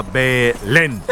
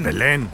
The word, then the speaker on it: Belén: Se pronuncia Belén. – Eso dije, Belén. – Belén. ¡Belén! Con acento en la última.